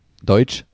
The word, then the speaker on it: Deutsch: Deutsch.